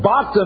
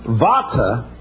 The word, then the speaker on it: bhata: B-H-A-T-A, bata, when you say it. So, instead of changing the spelling to a V, they just put an H in when it's to be pronounced with a V. So bata means ship. Vata means of a ship. Pronounced vata, not bata.